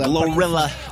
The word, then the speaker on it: GloRilla: The Gorilla, pardon, j'ai failli écorcher son blaze et dire Gorillaz. – Glorilla. – Glorilla. – Glorilla. – Ce L avec la langue pâteuse, Glorilla.